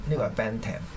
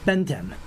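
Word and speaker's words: Bentham: 我提一提嗰個讀音啊，呢個係 Bantam。 – Bantam 一個 H 音唔讀嘅。 – OK，Bantam。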